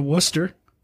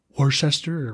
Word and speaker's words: Worcester: And it was like, and it's like, it's kind of a silent R, Bo. It's like Worcester.